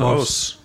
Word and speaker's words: hausse: Os? – Ja, nee, os. – Maar je zegt os. – Nou ja, je schrijft H-A-U-S-S-E. Dus dat zou je, het Frans woord moet je uitspreken als een os.